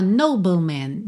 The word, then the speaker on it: nobleman: Atenção que a gente tem um E aí que não é pronunciado. E aí, como é que você faz esse BL, né? Fica bull. Do B, você vai pro L, língua no céu da boca, tá? Nobleman.